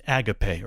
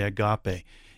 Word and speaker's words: Agape: Like in Western Europe, it's pronounced Agape, or as we would say, Agape.